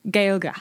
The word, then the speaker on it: Gaeilge: Gaeilge. – Gaeilge. – Gaeilge. So Irish in the Irish word is Gaeilge.